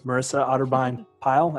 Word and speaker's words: Otterbein-Pyle: All right, there you have it, Myrissa Otterbein-Pyle.